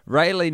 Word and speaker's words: Raleigh: Raleigh, North Carolina is pronounced Ray, think ancient Egyptian sun god, Lee, think, well, don't think, don't even worry about that part, you've already nailed it.